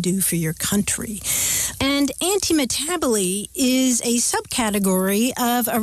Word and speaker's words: antimetabole: And there are a couple of rhetorical terms that we can talk about, one of which is antimetaboly. This is an example of antimetaboly, which isn't one of your long-lost relatives. It's spelled A-N-T-I-M-E-T-A-B-O-L-E, anti-metaboly. And that comes from Greek words that mean something like opposite turning about or opposite change.